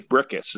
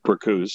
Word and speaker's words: Bricusse: Leslie Bricusse is his actual pronunciation of his name, but a lot of people call him Bricusse, so don't feel bad.